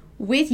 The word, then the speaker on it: with: A pronúncia de with, ela é um pouco tricky, ela é um pouco dificilzinha para os brasileiros que estão aprendendo inglês. A gente tem que fazer o som do Z com a língua presa, ok?